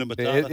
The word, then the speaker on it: Hakuna Matata: Hakuna Matata.